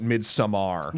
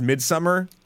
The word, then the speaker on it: Midsommar: I've just recently started hearing people call it Midsommar. – Yeah, I know that's how it's spelt. Yeah. It was always Midsummer or Midsommar.